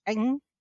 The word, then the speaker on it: Ng: The same author, Celeste, and I hope I'm not mispronouncing it, but I believe it's Ng. Last name is spelled NG.